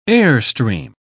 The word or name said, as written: airstream